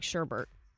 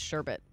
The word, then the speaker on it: sherbet: Just like Sherbert. It's sherbet.